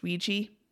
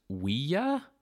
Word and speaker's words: Ouija: It's pronounced Ouija. – I mean, the official pronunciation. – If you really think about it, it should probably be pronounced Ouija, but no one says that.